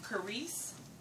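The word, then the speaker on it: charis (χάρις): And the Hebrew, or the Greek, I'm sorry, is hard to pronounce, but it's charis. That's C-H-A-R-I-S. Charis.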